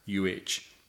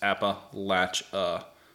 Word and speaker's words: Appalachia: It's Appalachuh. And it's just Appalachia. And it says, I don't care how your professor said you're supposed to pronounce it. So that's how we say it is Appalachia.